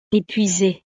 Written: epuise

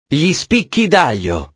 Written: gli spicchi daglio

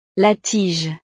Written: la tige

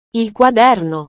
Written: il quaderno

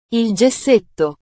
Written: il gessetto

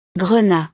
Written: grenat

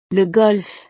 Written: le golf

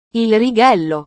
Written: il righello

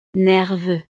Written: nerveux

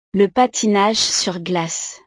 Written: le patinage sur glace

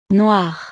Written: noir

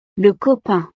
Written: le copain

